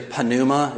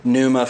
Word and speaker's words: pneuma: It's actually pneuma is the way it's pronounced, but we pronounce it, you know, in English, I guess, pneuma for pneumatic.